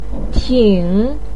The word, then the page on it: ting3: pinyin